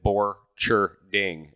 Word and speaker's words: Borcherding: And I actually did Borcherding is how I... – That is 100% how it is pronounced.